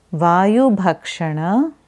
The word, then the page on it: Vayubhakshana: Sanskrit